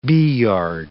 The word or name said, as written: beeyard